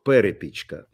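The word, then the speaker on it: Перепічка: Ну вот здесь пишут, что ударение на первый слог, перепичка.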